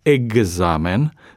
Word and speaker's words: examen: Dar vom pronunța exact sau examen sau exercițiu, adică cu G și Z, deși am scris cu X.